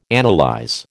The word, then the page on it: analyse: American English